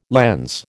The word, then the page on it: lens: American English